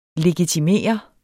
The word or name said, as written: LEGITIMERET